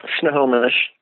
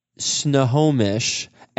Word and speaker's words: Snohomish: Snohomish. – Snohomish Economic Development Board.